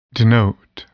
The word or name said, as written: denote